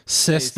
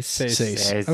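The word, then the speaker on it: seis: Ses de Mayo? Say? What's six? – Says.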